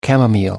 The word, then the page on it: chamomile: American English